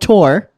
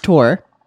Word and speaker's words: Thor: I'm sick of people saying Thor. It's called Tor. It's pronounced Tor.